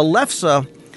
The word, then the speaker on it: lefse: And I made the mistake of pronouncing it wrong, so I thank Liz for giving me the correct pronunciation. A lefse...